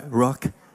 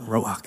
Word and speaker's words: Ruach: the next R uh, is Ruach, where my Hebrew people are. Jay Shelley. Am I saying that right? Ruach? Yeah, they got a thumbs up. Ruach,